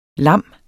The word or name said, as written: lam